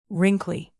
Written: wrinkly

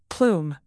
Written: plume

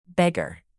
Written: beggar

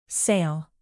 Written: sale

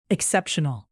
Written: exceptional

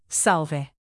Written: salve

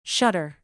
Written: shutter

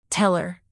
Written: teller

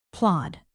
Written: plod

